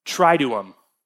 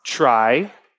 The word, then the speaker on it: Triduum: I had to look up how to pronounce it. You ready? Triduum. – Triduum. – Triduum.